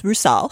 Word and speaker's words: Rusal: Well, it's Rusal. – Rusal. – Yes. – My Russian pronunciation.